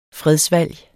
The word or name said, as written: fredsvalg